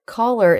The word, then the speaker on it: collar: Collar is spelled C-O-L-L-A-R, and color is spelled C-O-L-O-R. So we have an A-R ending and an O-R ending, but they are pronounced the same way, right, David? It's the schwa-R, sound.